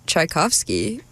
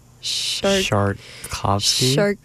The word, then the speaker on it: Tchaikovsky: Well, if you love sharks, then you should keep listening, because up next, we're hearing Tchaikovsky, which could be pronounced, if you're pronouncing it very, very badly, like Tchaikovsky? – Tcharkovsky? – Tcharkovsky? – Tcharkovsky.